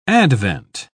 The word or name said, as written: advent